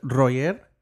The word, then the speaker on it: Roger: So in Spanish, you would pronounce it Roger. – How do you spell that? R-O-J? – No, R-O-G-E-R. Just R. The G is like soft instead of hard.